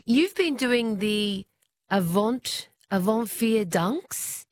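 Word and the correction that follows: Avondvierdaagse: Is that how you pronounce the festival? – Avond-Vierdaagse. – Avant-Vierdaagse.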